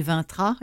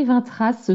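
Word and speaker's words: Vingtras: Ah, puis le merveilleux Blizzard aussi dont j'ai parlé, de Marine Vintra. Est-ce que je le prononce bien ou Vintras euh, c'est Marie Vintras, sauf erreur. Ah, c'est ça, exactement.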